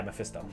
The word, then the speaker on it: Mephisto: I imagine Mephisto, that's how it's pronounced, right? – Yeah, Mephisto.